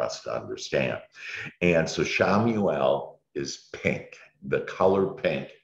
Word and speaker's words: Chamuel: So the first one is Archangel Shamuel. And that's for Sunday. And there's a color associated with every archangel. And Shamuel, and it's spelled Chamuel.